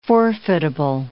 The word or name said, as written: forfeitable